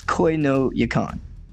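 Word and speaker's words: Koi no Yokan: You're doing it right. You're just adding a little bit too much emphasis on the Koi, right? Koi is like, it's real quick. You just fly through it. So it's Koi no Yakan. Yeah. You cut out the syllables. You just kind of flow through it.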